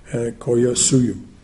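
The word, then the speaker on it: Collasuyu: Coyasuyu, C-O-L-L-A, the double L's are pronounced as a Y, Coyasuyu.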